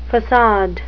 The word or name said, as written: facade